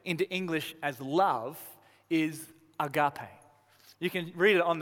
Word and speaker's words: agape: Those scribbles up there, that whatever that is, it means, or it's pronounced agape. Agape. Can you say it with me? Agape.